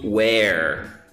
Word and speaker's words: where: Não confunda were, W-E-R-E, com where, que é onde. Quando eu pergunto where are you from? Where are you from? W-H-E-R-E.